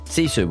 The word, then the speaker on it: SiSU: And in 2013, he launched his business, SiSU Health, pronounced SiSU, with the word SiSU meaning resilience and toughness in the Finnish language.